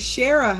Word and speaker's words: Shara: Shara. – I did it again. Hey, at least I'm phonetic. – Yes, yes. – Right? Okay. You want to introduce yourself? – My name is Shara Hutchinson.